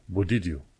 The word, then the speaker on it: but did you: Uh, but did you, așa se scrie, dar când se citește e budidiu.